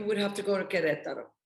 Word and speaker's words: Querétaro: Q-U-E-R-E-T-A-R-O. – Okay. – Querétaro. That's the capital Querétaro. City and the state. – Okay, Querétaro. All right. – You got it. You said it right.